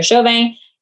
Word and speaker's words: Chauvin: My name, Heather Chauvin, and the podcast, Mom is in Control.